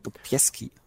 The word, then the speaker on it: Sobieski: ich hoffe, ich habe das jetzt richtig ausgesprochen. Sobieski. Ja, genau, genau so.